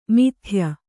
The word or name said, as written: ಮಿಥ್ಯ